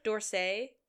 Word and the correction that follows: D'Orsay: D'Orsay. She's got a little God's comment in there. It's like a D apostrophe.